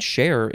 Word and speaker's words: chare: Next we have share, C-H-A-R-E, or you don't need the E. I think it's saying share is how you pronounce it.